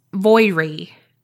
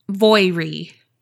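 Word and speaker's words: Voirrey: But I think it's Voirie. It's V-O-I-R-R-E-Y.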